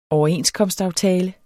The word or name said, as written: overenskomstaftale